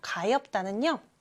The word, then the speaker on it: 가엾다: 가엾다는요, 비읍 하나만 남아서 가엾다. 이렇게 소리가 나요. 역시 다가 아니라 따가 되었죠.